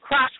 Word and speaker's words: Krush: When you type in my name is spelled Krush with a K, K-R-U-S-H. My last name is Kent, K-E-N-T.